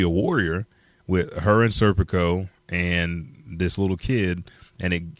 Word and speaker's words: Serpico: And I think they pronounce his name Serpico. So Serpico? – Serpico, yeah, that's right.